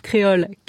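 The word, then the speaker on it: Kréyol: Alors je précise que donc c'est écrit avec l'orthographe créole K-R-E. Y-O-L, mais que ça se prononce quand même créole, m'a-t-on dit.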